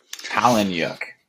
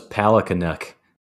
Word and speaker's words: Palahniuk: Palahniuk. – I thought it was Palahniuk. It's got a silent K.